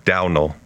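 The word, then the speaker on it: Domhnall: And it's pronounced Downel. – Downel? – Yeah, which I don't know how you get there.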